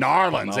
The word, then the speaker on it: New Orleans: Okay, what do the locals say? New Orleans Nolens. Or New Orleans? – Nolens. – Narlins. They say different.